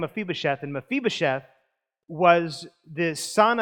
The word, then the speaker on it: Mephibosheth: This is a story, and this is how I believe you actually pronounce it. It's of a guy named Mephibosheth.